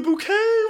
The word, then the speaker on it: Bucket: But it was spelt Bucket, but she insisted that it was actually pronounced Bouquet.